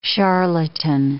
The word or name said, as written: charlatan